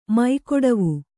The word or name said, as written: ಮೈಕೊಡವು